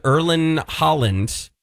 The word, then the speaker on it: Erling Haaland: Tom Holland, aka Spider-Man, apparently DM'd Erling Haaland.